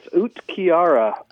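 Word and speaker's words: uhtceare: The one that it starts UHT, and it's lying awake feeling anxious. Man, we've had some of that. Lying awake feeling anxious. – Yes, Utkiara. – Oh, there we go. – Is how a stuffy professor would tell you to pronounce it in Old English.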